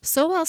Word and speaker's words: Sowell: Sowell stood out because of the way he wanted you to pronounce his name. He wanted it pronounced so well.